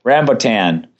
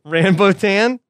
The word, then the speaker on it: rambutan: Choice number one, Ranbaten. Am I saying that right? – I think so. – What are those? Those the fruits? Rambutan. – Rambutan.